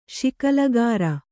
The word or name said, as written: ಶಿಕಲಗಾರ